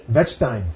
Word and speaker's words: Wettstein: Johann Jakob Wettstein, Swiss theologian. Pardon? – Wettstein, thank you. You want to say it right.